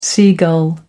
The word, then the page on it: seagull: American English